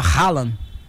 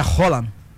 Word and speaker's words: Haaland: foneticamente falando, porque normalmente a gente escuta o pessoal, né, até eu mesmo falava Haaland, mas é Roland.